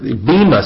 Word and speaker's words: Bema: Bema seat is what it is, not Burma seat. I was looking at my writing here. I couldn't even read my own note. But it said Bema seat that the church is going to go before.